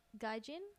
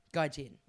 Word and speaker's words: gaijin: How do I say that word? – Gaijin? – Gaijin.